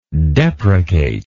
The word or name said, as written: deprecator